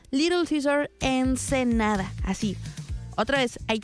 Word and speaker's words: Caesars: C-A-E-S-A-R-S. Caesar. Se pronuncia Caesar, pero es Caesar.